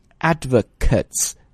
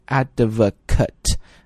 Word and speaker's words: advocate: But if you're going to say there are certain types of people who support some ideas, they are advocates. Okay? 如果讲的是人支持者，这个单词要读成 advocate. 啊，如果是动词 advocate, 啊，注意一下。拼法是一模一样的，就是那个 A 字母是 advocate 或 advocate。